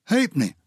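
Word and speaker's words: halfpenny: Hapenny. There's no F in Hapenny, is there? But it's a half penny, that's how you spell it.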